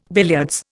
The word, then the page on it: billiards: Indian